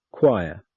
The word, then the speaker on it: choir: In these words, CH is k. Character, chemistry, choir, chorus, stomach, ache, anchor, architect, mechanic, orchestra, psychologist, technical.